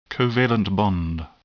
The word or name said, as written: covalent bond